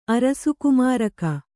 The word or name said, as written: ಅರಸುಕುಮಾರಕ